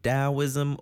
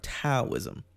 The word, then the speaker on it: Taoism: Taoism, you might hear it pronounced with a T or a D. Taoism or Taoism.